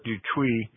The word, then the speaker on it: Detroit: And then when we got up to Fort Detuit, actually, most Americans pronounce that differently. They pronounce it Detroit.